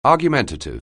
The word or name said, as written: argumentative